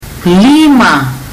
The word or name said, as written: lima